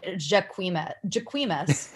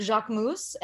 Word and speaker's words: Jacquemus: Well, that's like, there's a brand called Jacques Mousse. I was pronouncing it Jacquemus for my entire life. And then the other day, someone was like, Jacques Mousse. And I was like, what? – I'm excited to see House of Gucky.